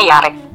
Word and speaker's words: Jarek: Jadi tulisannya J A R E K. Tulisannya Jarek, tapi namanya dibacanya Yarek.